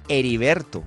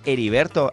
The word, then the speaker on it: Heriberto: Por ejemplo, Heriberto. No, no, no, no cambia nada la pronunciación de Heriberto a pesar de que tiene H.